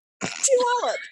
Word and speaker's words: Puyallup: But I literally had someone tell me to think of like pew pew Puyallup to like remember how to pronounce it.